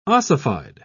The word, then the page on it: ossified: British English